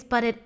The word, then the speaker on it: Buti: And it is spelled B-U-T-I, but I'm going to tell you right now, it is absolutely bootylicious. So yes, the way it sounds is the vibe that you're going to get from practicing this particular discipline.